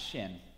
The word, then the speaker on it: Qin: The Qin Dynasty, spelled Q-I-N, pronounced Shin.